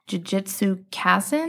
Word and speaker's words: Jujutsu Kaisen: So yeah, went on Crunchyroll, found that, but I started watching Jujutsu Kaisen. Mm-hmm. Is that how you pronounce it? – Mm-hmm.